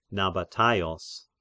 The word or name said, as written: Nabataios